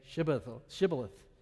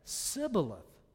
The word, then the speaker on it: Shibboleth: When he said no, they said to him, then say Shibboleth. See, I can't say it either. And he said Sibboleth, for the people could not pronounce it right.